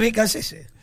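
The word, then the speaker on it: Beccacece: Ahora es Becachese. – Tenemos que unificar criterios. Tiene que ahora viene el Mundial, hay que pronunciar todo parecido.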